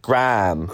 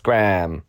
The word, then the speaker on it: Graham: but they say Graham in America. Yeah. Graham, no H, just Graham.